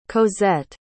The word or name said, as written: cosette